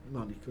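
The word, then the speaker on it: Modrić: Modric, ho detto bene, sì.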